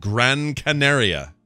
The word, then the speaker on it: Gran Canaria: The flight was heading to the Spanish island of Gran Canaria.